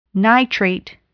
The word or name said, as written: nitrate